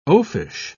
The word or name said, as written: oafish